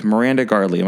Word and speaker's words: Miranda Gardley: Um, tonight, I am here with Miranda Gardley.